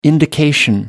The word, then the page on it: indication: American English